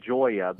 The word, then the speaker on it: Gioia: It's pronounced Joya, but it's spelled GIOIA.